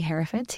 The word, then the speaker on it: Herefords: And predominantly Herefords.